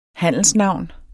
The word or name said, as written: handelsnavn